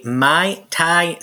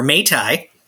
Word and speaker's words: Mai Tai: It's called a Mai Tai Sour, or Mai Tai. I don't know the exact pronunciation on it. It is I think you had it right the first time. – Yeah, let's go with number one.